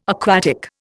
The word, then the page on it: aquatic: Indian